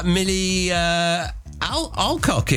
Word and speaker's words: Millie Alcock: Millie Alcock is now Supergirl.